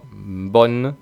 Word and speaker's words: bonne: Por ejemplo, en bonne tiene que sonar claramente la N. Bonne.